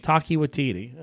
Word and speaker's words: Taika Waititi: Let me get his name right. Taika Waititi. Taika Waititi. Okay, I was close.